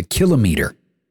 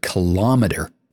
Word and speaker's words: kilometer: Kilo meaning 1,000, and yes, meter. But guess what? I bet you've heard tons of people butcher that pronunciation and use the word kilometer instead, which is, as I've painstakingly laid out here, much to your exhaustion, wrong.